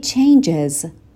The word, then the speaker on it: changes: It changes. به تلفظ change وقتی اسم میگیره دقت کنین. It changes. یک صدای ای بهش اضافه میکنیم.